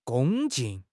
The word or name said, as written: 恭敬